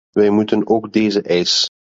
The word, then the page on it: eis: Dutch